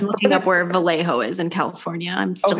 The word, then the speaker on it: Vallejo: They decided to bring her to Huntington Beach, her hometown, away from the commotion of the authorities in Vallejo.